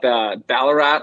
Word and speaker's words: Ballarat: I know you got a unique story as well, being from, I looked it up, Ballarat, Australia.